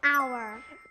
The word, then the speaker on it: hour: hour. – 네, 아주 잘했어요. 여기 h 소리가 안 나요.